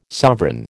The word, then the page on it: sovereign: American English